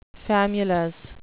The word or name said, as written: famulus